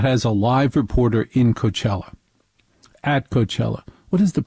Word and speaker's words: Coachella: I'm Velo Hul at Coachella, which rhymes with hella.